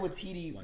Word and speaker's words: Waititi: Taika with Watiti. Yeah. yeah, I think you Taika Waititi.